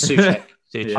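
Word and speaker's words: Souček: Yeah, Sucek or Sucek, however you say it. – Neither of those. Sucek. – It is Sucek. I can pronounce that one. – Yeah. Well done. Two syllables.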